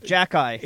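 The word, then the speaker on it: Xhekaj: The X-H-E-J-A-J. – Oh, yes, it's pronounced Jack-Eye. – It's Arbor Jack-Eye.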